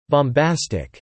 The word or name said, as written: bombastic